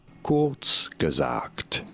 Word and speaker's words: Kurzgesagt: Ah, ouais, Kurzgesagt, qui se prononce comme suit. – Kurzgesagt. C'est ça j'ai dit.